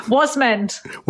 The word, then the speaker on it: Wasmund: Now, Shaa, how do you say your surname? Because I want to say it the Indian way. So... – Wasmund.